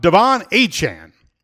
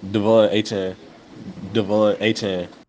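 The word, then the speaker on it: Achane: about Devon Achan. That is the way he wants his name pronounced.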